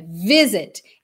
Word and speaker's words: visit: Percebe que a gente não fala visit, é visit. Então, o vi é mais forte.